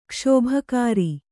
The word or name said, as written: ಕಸಬುಗಾರ